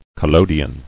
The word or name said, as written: Collodion